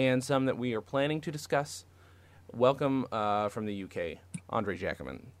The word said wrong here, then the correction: Jacquemin: I said I did it with the soft J. Let me try it again. Andre Jacquemin, thank you for being here.